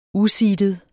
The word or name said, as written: useedet